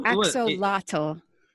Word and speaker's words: axolotl: No, that's not how you oh, pronounce that word. axolotl.